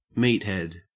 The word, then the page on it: meathead: Australian English